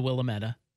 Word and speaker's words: Willamette: And then, of course, Willamette is on here, and we all know it's pronounced the Willamette.